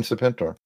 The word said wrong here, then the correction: Serpentor: Serpentor, yep. I'm sorry, I mispronounced.